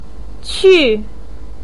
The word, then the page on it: qu4: pinyin